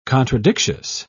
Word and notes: contradictious: British English

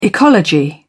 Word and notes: ecology: British English

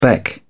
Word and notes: beck: British English

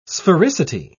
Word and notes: sphericity: British English

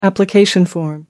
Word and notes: application form: American English

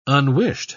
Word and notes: unwished: British English

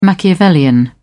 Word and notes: Machiavellian: British English

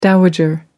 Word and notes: dowager: American English

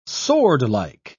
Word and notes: swordlike: British English

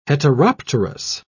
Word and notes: heteropterous: British English

